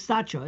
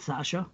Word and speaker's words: Sacha: But you know, his name is Sacha. It's not Sasha.